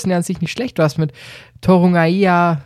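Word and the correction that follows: Torunarigha: sorry für die Aussprache. Torunariga, jetzt. – Richtig.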